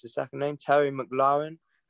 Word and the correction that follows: McLaurin: Terry McLaurin. Okay, that's a wide receiver for the Redskins right there.